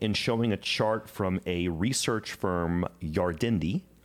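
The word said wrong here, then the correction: Yardeni: and I probably murdered the pronunciation of this research firm. – I think he's a person. I think his name's Ed Yardini. – Yardini? – Yeah.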